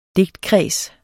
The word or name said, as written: DIGTKREDS